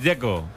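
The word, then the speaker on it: Džeko: Dice que es Dzeko. – Como tirando a J, Dzeko o algo así.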